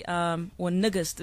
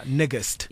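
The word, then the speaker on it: Nigist: So niggist is actually, niggist is actually how it's pronounced. – Yeah, niggist.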